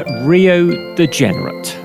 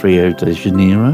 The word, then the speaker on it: Rio de Janeiro: We just left the bar of the Spotted Reindeer, which we all know is the Sugarloaf Mountain pub Rio de Generate. – No, I think you'll find it's pronounced Rio de Janeiro, but never mind.